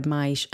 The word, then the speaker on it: mais: a gap in between that word maish and o, I would pronounce the s as a sh, you know, as I just did, maish.